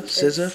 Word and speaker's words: SZA: Oh, SZA, C- oh, SZA, SZA, SZA.